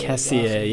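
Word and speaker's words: Kessié: The old, like, Frank Kessier, I think is how you say Kessier, yeah, the midfielder, right?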